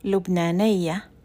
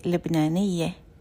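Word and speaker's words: لبنانية: So in Egyptian Arabic, you say Lubnaniya, and in Levantine Arabic, you say Lubnaniye.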